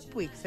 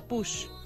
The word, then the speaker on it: Puig: No se pronuncia Puig, se pronuncia Push.